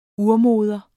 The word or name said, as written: urmoder